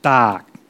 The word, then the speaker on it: Tak: It's spelt T-A-K, but if you pronounce it correctly, it's pronounced Dak, kind of like dark, but with a T.